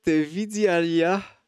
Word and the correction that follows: Vidyalaya: Non, Vidyalaya en Inde.